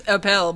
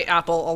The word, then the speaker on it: Appel: It's technically it's Appel, but I go by Apple a lot.